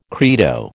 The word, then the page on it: credo: American English